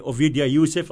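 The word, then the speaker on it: Ovadia Yosef: The chief Sephardic rabbi, Ovidya Yosef, am I pronouncing his name correctly, Stephen? – Mm-hmm.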